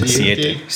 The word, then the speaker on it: Siete: Siete.